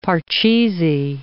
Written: Parcheesi

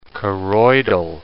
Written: choroidal

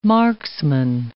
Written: marksman

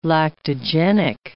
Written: lactogenic

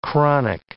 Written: chronic

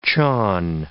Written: chon

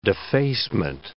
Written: defacement

